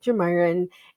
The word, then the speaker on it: Geomyra: So my parents are George and Myra, and so they named me Geomyra. And so it's a tricky name. I tell people, I always joke that my husband mispronounces my name sometimes too, but George and Myra is how you get Geomyra.